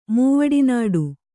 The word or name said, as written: ಮೂವಡಿನಾಡು